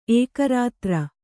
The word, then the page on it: ಏಕರಾತ್ರ: Kannada